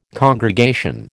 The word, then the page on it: congregation: American English